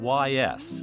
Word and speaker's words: Ys: The title of Joanna Newsom's new record is spelled Y-S and pronounced Ys, and it refers to a mythical druid city built by King Gradlon once upon a time in Brittany for his daughter, Dahut.